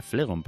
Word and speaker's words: Flegón: Pues hoy es Santa Julia Biliard, hoy 8 de abril, San Dioniso de Corinto, San Asíncrito, San Flegón, sí será Flegón porque no lleva tilde en el O, San Flegón, San Herodión, San Dioniso de Alejandría, San Agabo, profeta, San Amancio de Como.